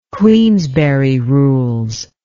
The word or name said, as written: Queensberry rules